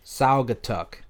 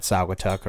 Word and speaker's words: Saugatuck: Saugatuck. – Saugatuck Brewing Company. – Sorry if I butchered that, Saugatuck or Saugatuck.